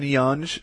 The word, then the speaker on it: Yonge: So on Yonge Street, I think that's how you say it, it's Y-O-N-G-E.